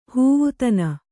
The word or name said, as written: ಹೂವುತನ